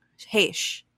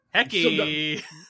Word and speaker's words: Heche: Heche is the pronunciation. – Yes. – Yeah. – Yeah, so, I forget what Mike was going with earlier, but it was Hecky.